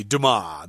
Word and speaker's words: Dumas: Excuse me, Dumas. The S, is, I guess, is silent in French there.